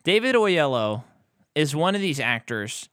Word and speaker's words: Oyelowo: How do you say that guy? – Oyelowo, right? – Oyelowo. – I think it's Oyelowo.